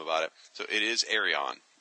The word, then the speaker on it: Arion: Now, by the way, that is the official pronunciation, Arion.